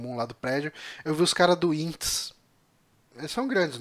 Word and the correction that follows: INTZ: É, são, é INTZ.